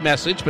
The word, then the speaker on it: Meccage: M-E-C-C-A-G-E. Well, he doesn't spell it wrong. That's his name. Spell it wherever he wants. But it doesn't spell. It doesn't look like Message, but that's how you say it.